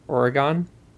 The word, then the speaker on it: Oregon: Well, how do you say the name of that state again? – Oregon. – Okay. – Like Polygon? – Sure.